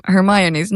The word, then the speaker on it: Hermione: Och så läste jag Harry Potter och jag orkar aldrig läsa igenom Hermiones namn. Eller som min mamma högläste sen senare, och då fick jag veta att det uttalas Hermione på svenska.